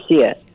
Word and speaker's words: Artemisia: Is it Artemisia? – Did I say it correctly? – Artemisia. Yes, Artemisia. I went back to ancient Greek and Roman times and looked into famous female mathematicians, and there was one, Hypatia.